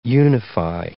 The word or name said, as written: unify